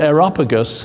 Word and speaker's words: Areopagus: And the Areopagus, said it right that time, was where the philosophers would meet and they would debate one another.